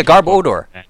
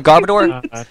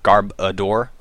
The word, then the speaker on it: Garbodor: People call it Garbodor. It's a pile of garbage. – It's pronounced garb odor, like, you know, odor, so garb odor, not garb adore, like people seem to want to pronounce it.